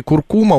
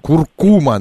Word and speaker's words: куркума: Нас кто-то подправил, что в слове куркума ударение на А. Нет, куркума на У вторую. – Куркума, правильно, да.